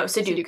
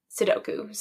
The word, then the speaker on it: Sudoku: No, Sudoku. – Sudoku, yeah.